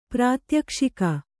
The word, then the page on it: ಪ್ರಾತ್ಯಕ್ಷಿಕ: prātyakṣika